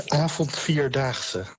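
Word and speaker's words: Avondvierdaagse: You've been doing the Avant-Vierdaagse. Is that how you pronounce the festival? – Avond-Vierdaagse. – Avant-Vierdaagse.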